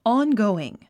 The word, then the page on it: ongoing: ɑ́ngòuiŋ; オンゴウイン